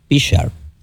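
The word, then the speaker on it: Prichard: Déjame pronunciar bien, porque algunos dicen Richard y es Pichard, con P.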